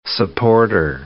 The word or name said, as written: supporter